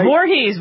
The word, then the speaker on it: Voorhees: Voorhees.